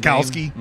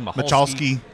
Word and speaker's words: Michalski: When he got down toward the end of the interview, okay, his last name is spelled M-I-C-H-A-L-S-K-I. – Right. – Lots of us been mispronouncing it for years till he started playing. We found out that the C was silent.